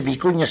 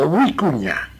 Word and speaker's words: vicuña: Tiene doble, la doble B, no se dice vicuña, sino huicuña.